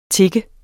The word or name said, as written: tik